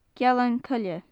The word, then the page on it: gealan-coille: gaelic